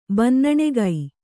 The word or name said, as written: ಬಿನ್ನಣಿಗೆ